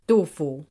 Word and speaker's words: Du Fu: How do you say his name? – Du Fu. – Du Fu, yeah.